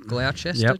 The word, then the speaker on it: Gloucester: So, yeah, they roll, I don't know if I'm saying this right, but Gloucester cheese? – Yep. – Gloucester cheese?